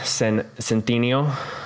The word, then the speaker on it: Centineo: The acting, it was good acting from everybody, especially from Noah Centineo. I would assume that's how you pronounce his name, right? – Sounds and looks right.